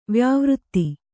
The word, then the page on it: ವ್ಯಾವೃತ್ತಿ: vyāvřtti